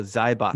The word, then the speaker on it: Zaibatsu: those U's are often not pronounced super much. So Zaibots.